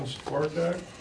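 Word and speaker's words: Stvartak: John Spartak. – Spell that. – 7250 Navarre. – Spell the last name for me. – S-T, V as in Victor, A-R-T-A-K. – Just like it sounds.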